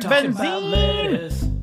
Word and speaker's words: Benzine: My name is Craig Benzine. – I never realized Craig Benzine kind of rhymes with magazine.